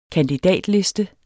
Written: kandidatliste

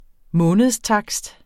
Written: månedstakst

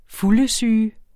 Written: fuldesyge

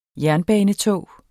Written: jernbanetog

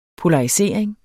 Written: polarisering

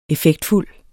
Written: effektfuld

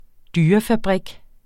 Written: dyrefabrik

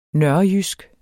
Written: nørrejysk

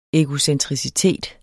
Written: egocentricitet

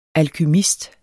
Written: alkymist

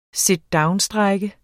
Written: sitdownstrejke

